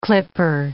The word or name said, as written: clipper